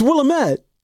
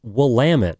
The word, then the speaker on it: Willamette: Are we not pronouncing the W? What the hell's wrong with it? It's Willamette. – Oh, my God. Dude, I went to school there. – Willamette. – Wow, dude. – It's pronounced Willamette.